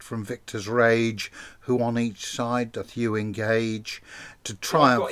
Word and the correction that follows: encage: In cage. – Yeah, in cage. Sorry, that was my mispronunciation.